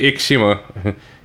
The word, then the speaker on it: Ikishima: It's more than likely Ikishima. You goddamn gaijin. – What did I say? – Ikishima. You pronounced the second I.